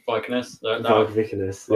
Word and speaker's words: Vikernes: Vikernes.